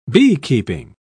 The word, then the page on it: beekeeping: British English